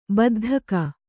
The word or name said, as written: ಬಿದ್ದಿಕ್ಕು